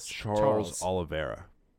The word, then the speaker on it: Charles Oliveira: And last but not least, we have Kevin Lee versus, I already forget how to pronounce this, Charles Oliveira. – Against Charles in charge. – Just say olive and then era.